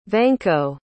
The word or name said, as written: vanko